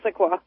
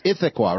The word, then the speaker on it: Issaquah: Let's get to listening on Free Speech, and she's calling from Issaquah, Washington, if I'm saying that correctly. – Yeah. It's Issaquah. – It's Issaquah, right? Okay.